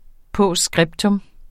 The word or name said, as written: POSTSCRIPTA